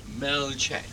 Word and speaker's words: Melchett: Happy birthday today to let us see, Lord Mel, how do you pronounce that? – Melchek. – Yeah, I'm just getting it checked, you see, because we could get ourselves into an embarrassing situation if we mispronounce Melchett's name.